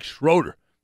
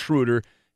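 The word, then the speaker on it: Schröder: His name is pronounced Schroeder. It looks like Schroeder, but it's pronounced Schroeder.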